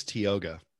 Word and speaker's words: Tioga: And then this is from, how do you say it? – I believe it's Tioga.